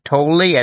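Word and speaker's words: Tolle: But the guy you mentioned is Payson Toley, as in holy moly.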